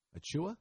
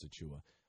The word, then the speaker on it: Achiuwa: Precious, how do you say Precious's last name? Achiuwa? – That sounds right. – Yeah, I think it's Precious Achiuwa.